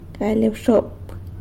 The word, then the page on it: galy abxop: sanlucasquiavini